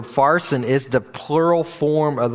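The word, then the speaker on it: pharsin (פַּרְסִין): Now, I know that some of your translations say parson, but the actual word is, it's pronounced with a PH sound. It's pharsin.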